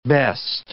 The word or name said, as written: best